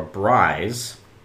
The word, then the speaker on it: braai: So in recognition of South African culinary traditions of holding informal backyard barbecues or braais, which I believe is how you pronounce it, B-R-A-A-I's, South Africans all across the country will have braais with their own regional dishes.